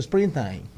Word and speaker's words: Springsteen: ¿Cómo se pronuncia eso? Springsteen. Bruce Springsteen.